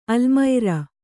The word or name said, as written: ಆಲಮರು